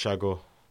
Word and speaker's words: Sago: It's actually pronounced Shago.